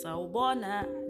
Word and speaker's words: Sawubona: Sawubona. – Sawubona. Did I say that right? – Yes, you did.